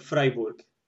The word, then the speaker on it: Fribourg: So this is how you pronounce this city. Of course, I know it's quite a well-known city. I know it like Freiburg. – Yeah. – Is it the same? – Yeah, it's the same.